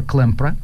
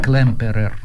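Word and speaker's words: Klemperer: Впервые подробно их описал Виктор Клемпра. Его знают все. И по-русски он пишется «Клемперер» да, немножечко по-другому, чем звучит по-немецки.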